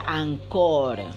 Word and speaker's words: Anchor: Se escribe Anchor, pero se pronuncia Ancor, que es lo que yo le he escuchado a otros, eh, digamos, este, youtubers.